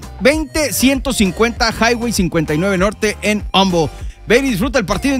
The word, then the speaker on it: Humble: Humble. Ya me corrigieron, no se dice Humble, se dice Humble.